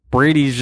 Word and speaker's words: Brady: Chiesa's striking might be worse than Bradley's. – Yeah. – Sorry, it's Brady.